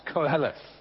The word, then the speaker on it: Koheleth: So probably if you're just doing a direct transliteration, it shouldn't have the U in it, okay? It is pronounced Koheleth, okay?